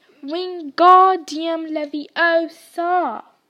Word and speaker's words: Wingardium Leviosa: You're saying it wrong, Harry heard Hermione snap. – It's Wingardium Leviosa. Make the gar really long.